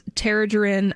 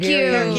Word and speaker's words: Targaryen: Thank Targaryen. – You. – Targaryen, you're right.